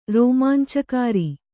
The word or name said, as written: ರೋಮಾಂಚಕಾರಿ